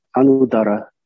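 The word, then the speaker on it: 阿 努 达 拉 (अनुत्तर): 但是梵文的发音的话就是阿努达拉，阿努达拉，就我们不是说，有时候说这个，就是序部里面也有分这个，就是阿努达拉苏彻，阿努达拉坦查，或者是说阿努尤伽，阿努就是那种的。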